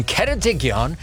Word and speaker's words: Ceredigion: Well, Holly Cookies in Ceredigion in Wales. Hello, Holly. Happy birthday. – Hi. Thank you. What a lovely pronunciation of Ceredigion.